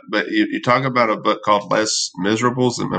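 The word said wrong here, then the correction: Les Misérables: It's French. So if you want to be very French, you say Les Miserables.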